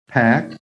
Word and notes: PACK: American English

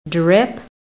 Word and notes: drip: American English

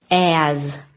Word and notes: AS: American English

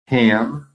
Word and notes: HAM: American English

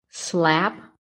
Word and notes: SLAP: American English

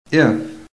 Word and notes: IF: American English

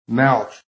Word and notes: MOUTH: American English

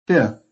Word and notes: FIFTH: American English

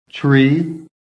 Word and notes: TREE: American English

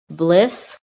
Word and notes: BLISS: American English

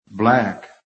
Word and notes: BLACK: American English

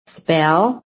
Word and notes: SPELL: American English

